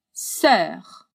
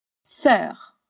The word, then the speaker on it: sœur: Sœur. Essa palavra tem uma só sílaba e causa um pouco de dificuldade pelo e dans l'eau, literalmente o e dentro do o.